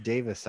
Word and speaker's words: Davies: So you get a left, it's pronounced Davis, I think. – All the English folks are saying Davis, but I'm pretty sure Davies, it's spelled Davies, Davies, so I'm going to say Davis. – Can we call him because it's a Welsh thing. It's so like the Welsh say, they spell it that, but they say Davis.